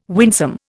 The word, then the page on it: winsome: Indian